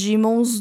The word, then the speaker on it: Daemon: E em inglês eles pronunciam Demon, mas como eu li o livro em português, eu falo Daemon, porque é assim que se fala em português, eu acho.